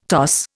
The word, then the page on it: toss: Indian